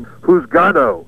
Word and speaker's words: juzgado: Juzgado, and that word is spelled J-U-Z-G-A-D-O. And, you know, again, kind of like dar la vuelta. We didn't quite get what they were saying, juzgado, so we turned it into who's gal.